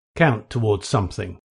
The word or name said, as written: count towards